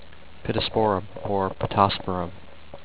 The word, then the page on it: Pittosporum: pit-tos-POUR-um